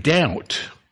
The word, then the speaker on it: doubt: Did you know the word doubt? D-O-U-B-T. Where did the B come? You don't pronounce it.